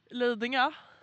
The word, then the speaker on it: Lidingö: Lidingö.